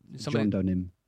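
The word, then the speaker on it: JonDonym: And it's a Firefox that's specifically modded to use the John Doman service. I think I'm saying that right. John Doe, N-Y-N? – John Donim.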